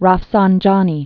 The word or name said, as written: Ali Akbar Hashemi Rafsanjani